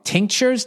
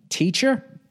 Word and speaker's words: tinctures: Tinctures? T-I-N-C-T-U-R-E-S?